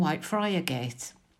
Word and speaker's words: Whitefriargate: We now move to the junction in front of you, Princess Dock Street and Whitefriar Gate. Here in Hull, we pronounce this Whitefriargate, just one of Hull's many idiosyncratic pronunciations.